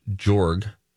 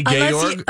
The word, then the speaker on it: Georg: G-E-O-R-G, which you just pronounced Jorg. I believe it's the same spelling of a character from The Sound of Music. So I believe the internet tells me it would be pronounced Georg.